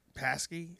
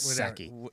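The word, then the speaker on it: Psaki: Paskey? Saskey? – The P is silent. – It's Saki. Saki.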